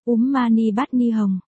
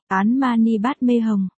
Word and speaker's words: Om Mani Padme Hum: Chân ngôn 6 chữ, Om Mani Padme Hum, đọc là, Úm Mani Padme Hum, hay, Án Mani Padme Hum.